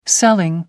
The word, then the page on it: selling: American English